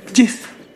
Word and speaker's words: GIF: Algunas personas pronuncian Jeff.